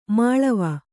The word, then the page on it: ಮೇಳಾವ: mēḷāva